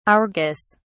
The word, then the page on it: argus: British English